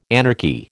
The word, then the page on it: anarchy: American English